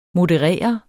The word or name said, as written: moderere